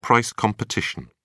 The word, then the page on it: price competition: British English